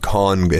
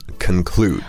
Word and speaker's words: conclude: You might want to pronounce this conclude, not con, it has C-O-N,、but it's conclude.